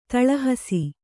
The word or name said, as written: ತಿಳುಹಿಸು